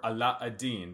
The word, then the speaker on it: Aladdin: And one of these stories was Aladdin and his wonderful, or Ala-Adin, that's how the Arabic pronunciation, and his wonderful lamp.